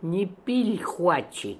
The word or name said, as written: Nipilhooatchi